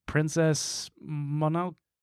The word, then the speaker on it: Princess Mononoke: Princess Mononoke.